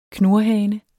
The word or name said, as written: knurhane